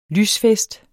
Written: lysfest

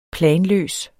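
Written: PLANLØS

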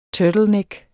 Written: turtleneck

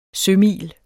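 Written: sømil

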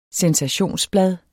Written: sensationsblad

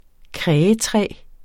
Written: krægetræ

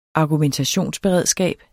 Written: argumentationsberedskab

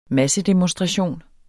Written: massedemonstration

